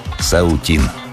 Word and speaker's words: Саутин: После чего восхищенные мужеством россиянина комментаторы из Пекина стали произносить его фамилию на китайский манер с ударением на последний слог. Саутин. Признали.